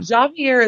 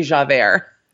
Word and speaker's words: Javert: Javert is a very Lin-Manuel Miranda way to pronounce his name. I would say Javert.